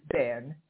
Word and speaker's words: Bern: I am pronouncing it as it would be pronounced by the Swiss, which is Bern.